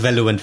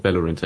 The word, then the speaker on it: Valorant: Also Valorant wird's ausgesprochen. Genau, Valorant, ja.